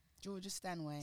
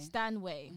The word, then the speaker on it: Georgia Stanway: Like, my favourite player right now, I can't pronounce her last name, but it's Georgia Swanley or something like that. – Georgia Stanway. – Stanway.